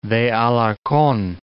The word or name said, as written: Taxco de Alarcon